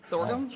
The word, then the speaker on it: sorghum: Sour gum. Sour gum. – Sorghum? – Sorghum.